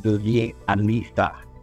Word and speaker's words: Dozie Alisah: Well, hi, my name is Dozie Alisah.